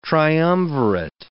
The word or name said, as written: triumvirate